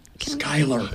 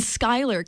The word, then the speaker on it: Schuyler: Schuyler. – In Schuyler County, thank you.